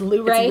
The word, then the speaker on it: Luray: Like, you think it's Luray.